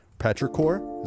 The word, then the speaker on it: petrichor: Petrichor, is that cool. – the right pronunciation of that word? – Yep.